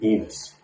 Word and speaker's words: Enos: You don't hear a lot of new babies being named Enos these days, or Roscoe. – Rhymes with penis.